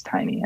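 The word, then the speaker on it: Tainy: By the way, it's not Taney, it's Tiny. I mispronounced his name.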